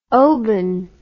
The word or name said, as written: oban